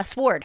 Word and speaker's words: sword: A sword. – A sword. Oh, my God. Stop it. Tell me why the W is silent.